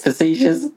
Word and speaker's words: facetious: What's the word? – Fajitas. – How do you say it? Facetious? – Facetious. Facetious. I'm being a little bit fajitas.